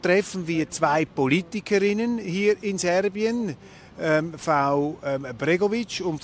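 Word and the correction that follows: Begović: Frau Brnabic, habe ich das richtig ausgesprochen? – Frau Begovic, genau. Molekularbiologin, die eine, Wissenschaftsministerin und die andere Parlamentspräsidentin, bis vor kurzem Premierministerin.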